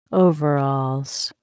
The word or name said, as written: overalls